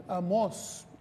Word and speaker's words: Amós: No, Amós, tiene acento en la O.